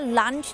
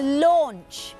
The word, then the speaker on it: launch: ซึ่งคำนี้หลายๆคนเนี่ยมักจะอ่านว่า lunch นะคะแต่ว่าไม่ใช่ lunch นั้นมันก็คือมื้อกลางวันใช่ไหมคะอาหารกลางวันจริงๆแล้วต้องออกเสียงว่า launch